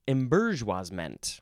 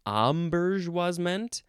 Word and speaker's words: embourgeoisement: You can also pronounce it embourgeoisement. Oh, and then, of course, this very Frenchy, probably most accurate way is, I'm not going to say it correctly, it's embourgeoisement. Embourgeoisement. That's close enough.